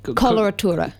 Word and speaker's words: coloratura: Coloratura.